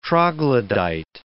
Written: troglodyte

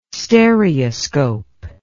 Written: stereoscope

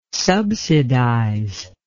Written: subsidize

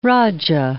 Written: raja

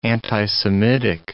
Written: anti-Semitic

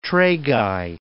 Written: tragi

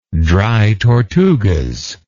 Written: Dry Tortugas

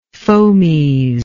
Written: fomes

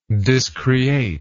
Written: discreate

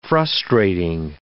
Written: frustrating

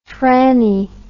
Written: freni

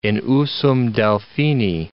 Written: in usum Delphini

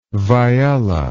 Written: viyella

